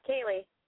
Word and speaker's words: Kayleigh: Oh, Kaylee. – Kaylee. Can you spell that for me? – K-A-Y-L-E-I-G-H.